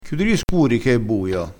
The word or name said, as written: scuro